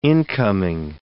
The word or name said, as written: incoming